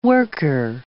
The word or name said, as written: worker